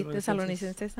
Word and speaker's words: Tesalonicenses: El primero Tesalonicenses. ¿Correcto? – Sí, Tesalonicenses. Ah, you said it right.